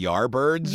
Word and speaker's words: Yardbirds: When you have heard of the band the Yardbirds. – Yes. – Well, it's Yard birds, and a lot of people don't pronounce the D very well.